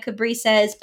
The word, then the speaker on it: Cabrices: It is the word cab, like a taxi, rice, which I love to eat. And then you throw an S on the end of it and you have Cabrices.